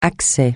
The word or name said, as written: accès